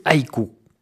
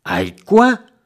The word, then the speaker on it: haïku: Haïkoi? Haïku! Cela s'écrit avec un U en français, mais ce mot d'origine japonaise se prononce haïku.